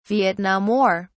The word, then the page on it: Vietnam War: British English